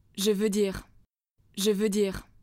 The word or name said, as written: Je veux dire «X»